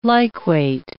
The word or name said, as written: liquate